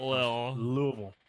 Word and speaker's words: Louisville: I mean, like if you look at how it's spelled, right? I think Louisville, right? Louisville. That's how most people would say it. But when you're from Kentucky, it just falls out of your mouth.